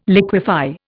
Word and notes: liquefy: Indian

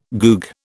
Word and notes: goog: British English

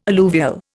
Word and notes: alluvial: Indian

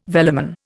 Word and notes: velamen: Indian

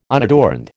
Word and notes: unadorned: American English

